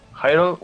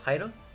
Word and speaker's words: Hyrule: Hero Warriors Legends. No, ¿cómo se dice? Hyrule, ¿no? Hyrule Warriors Legends. – Hyrule, ajá. Uh-huh. Como Harold, pero Hyrule.